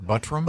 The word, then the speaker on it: Buttram: Wouldn't it be Buttram, like the actor Pat Buttram? – But the guy himself just kept saying, this is the butt ram. – The butt ram is how he pronounced it, and that's his name.